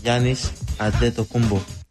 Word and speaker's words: Giannis Antetokounmpo: But just remember, my name is Giannis Antetokounmpo. – That is him speaking. Those are his words. How to pronounce his name.